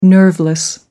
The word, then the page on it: nerveless: American English